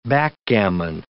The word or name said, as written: backgammon